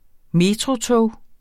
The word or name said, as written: metrotog